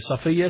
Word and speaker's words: Safiya: Safiya, it's pronounced Safiya. You can also say Safiya, and the meaning is pure, virtuous.